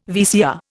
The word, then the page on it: VCR: Indian